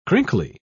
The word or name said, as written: crinkly